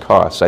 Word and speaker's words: Kos: It looks like Koos here, but it's pronounced Kos. I think new spelling is K-O-S.